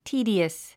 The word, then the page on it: tedious: tíːdiəs; ティーディアス